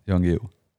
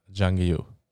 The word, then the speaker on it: Jan Guillou: Jan Guillou. Jan Guillou.